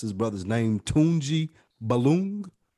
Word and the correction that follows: Balogun: or something of that nature. B-A-L-O, Balogun. Tunji Balogun.